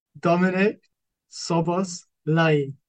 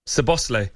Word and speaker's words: Szoboszlai: The correct pronunciation, please. – Dominic Szoboszlai. – Szoboszlai. – Szoboszlai.